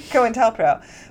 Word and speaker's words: COINTELPRO: Okay, that time you did say it absolutely differently than saying COINTELPRO. – COINTELPRO. Oops, I added a different, another syllable. – Yeah, you did. You added a syllable.